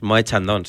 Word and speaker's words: Moët Chandon: Adrián Blanco, ayer me encontré en Instagram una story con un Moët Chandon. – Perdón. – Creo que se dice así. – Moët Chandon, sí.